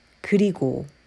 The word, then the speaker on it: 그리고: 그리고. And conversationally, a lot of Koreans would say 그리고, changing the vowel for the last syllable from O to U, 그리고.